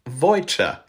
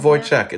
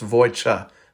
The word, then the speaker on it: Wojciech: In Polish, it's pronounced Wojciech. Fun fact. Yeah. Not Wojciech, it's Wojciech because CH in Polish is the equivalent of just H. So anyway, real lesson.